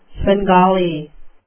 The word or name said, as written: svengali